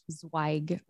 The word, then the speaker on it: Zweig: My last name is pronounced Zweig with a sharp I. Zweig.